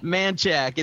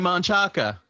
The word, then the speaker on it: Manchaca: It's funny when you read it, you want to say Manchaca. – Right. Well, I do the same thing when I'm reading it in my head. I say it wrong.